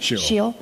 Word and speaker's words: Sheol: For you will not leave my soul in Sheol. Did I say that right? – Sheol.